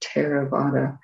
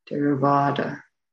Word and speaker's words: Theravāda: It's not like th, like we don't say Theravada, like the word therapy, although it's a nice link, I guess, but that would be a Theravada. So a T with an aspiration after it, Theravada. And then PH is not pronounced like F in Pali.